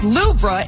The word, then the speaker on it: Louvre: The weight loss drug is high on the list, along with Storm Eowyn and the Louvre Museum, which is pronounced Louvre in France.